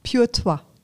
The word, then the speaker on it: Pure Toi: Pur, wie, wie, wie, wird die ausgesprochen? Pure Toi.